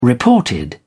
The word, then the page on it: reported: British English